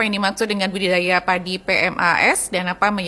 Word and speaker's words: PMAAS: Kalau kita biasanya untuk pengenalan awal itu PMAAS ya. PMAAS.